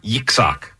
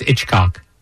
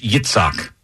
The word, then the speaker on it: Yitzhak: Yitzhak. – I've heard of him. Yixok. Somebody out there knows Yixok. – Yeah. – I think it's Itchcock. – No, it's Yitzhak. It's not Itchcock.